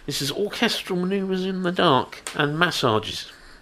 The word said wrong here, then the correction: Messages: Is that right? – Well, close, but a different pronunciation. – Oh, is it? – Yes. – Oh, it's Messages. – Messages.